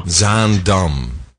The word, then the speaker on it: Zaandam: Z-A-A-N-D-A-M. – That's Zaandam. – Zaandam. – Zaandam.